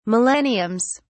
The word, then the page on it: millenniums: British English